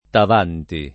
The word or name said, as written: Tavanti